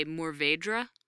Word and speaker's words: Mourvèdre: You look at it and how it's spelled, and I used to say Mourvèdre. If you say that to any French person, you will get corrected. Corrected, whether politely or not so much. But Mourvèdre is apparently roughly the pronunciation.